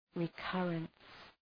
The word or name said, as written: recurrence